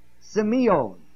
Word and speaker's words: semeion: This word here, and seeing the miracles, is the word semeion, spelled S-E-M-E-I-O-N. Put it down. It's a long E.